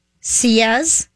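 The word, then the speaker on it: sillage: And they say the phonetic spelling is S-E-E and then Y-A-Z-H. Sias, but it's spelled S-I-L-L.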